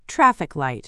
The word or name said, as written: traffic light